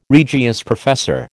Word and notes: Regius professor: American English

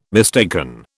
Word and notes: mistaken: British English

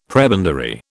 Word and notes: prebendary: British English

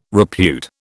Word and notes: repute: British English

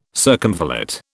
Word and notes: circumvallate: British English

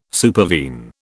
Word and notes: supervene: British English

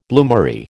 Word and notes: bloomery: American English

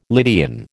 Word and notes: Lydian: American English

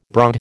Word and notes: broad: American English

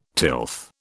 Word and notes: tilth: British English

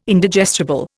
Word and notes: Indigestible: Indian

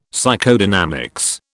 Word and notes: Psychodynamics: British English

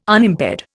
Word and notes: Unimpaired: Indian

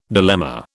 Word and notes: dilemma: British English

